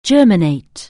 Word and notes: germinate: anglais

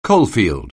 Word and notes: coalfield: anglais

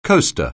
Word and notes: coaster: anglais

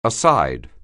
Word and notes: aside: anglais

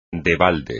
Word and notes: de balde: espagnol

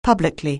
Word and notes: publicly: anglais

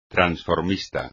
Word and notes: transformista: espagnol

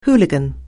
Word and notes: hooligan: anglais

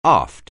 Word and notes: aft: anglais

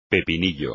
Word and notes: pepinillo: espagnol

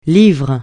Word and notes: livre: francais

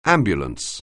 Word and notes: ambulance: anglais

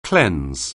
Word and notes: cleanse: anglais

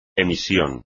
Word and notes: emisión: espagnol

